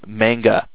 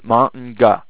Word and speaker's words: manga: when they do not pronounce manga as manga, they pronounce it as manga. It is not manga. Because that is not how Japanese is pronounced. It's manga, okay?